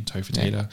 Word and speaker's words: Topher Taylor: Instagram is my name, Topher Taylor.